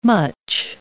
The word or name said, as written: much